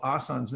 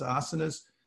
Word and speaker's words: asanas: And the reason is, actually, historically, asanas, the people call asans nowadays, it's actually pronounced asanas, but these are a place where you sit down to be more focused.